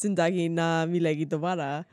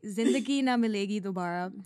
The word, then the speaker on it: Zindagi Na Milegi Dobara: And I mean, in return, you've shown me the masterpiece that is Zindagi Na Milegi Dobara. – Oh my God. – I totally butchered that. – That's okay. Zindagi Na Milegi Dobara.